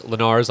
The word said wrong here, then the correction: Linares: like you're Harold Letterman. Linares? What? – Wait, how do you say it then? – Linares. – Linares, sorry.